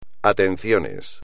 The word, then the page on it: atenciones: espagnol